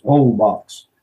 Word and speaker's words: Holbox: The name of the island's Holbox, but it's, they don't pronounce the H in Spanish. It's Holbox.